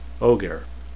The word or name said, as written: ogier